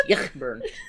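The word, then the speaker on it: Ayckbourn: I shouldn't be mean about Eichburn, but seriously, I can't pronounce that damn name. – No, I've told you, it's Yachtburn.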